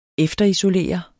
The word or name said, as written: efterisolere